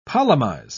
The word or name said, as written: polemize